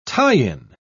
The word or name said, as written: tie-in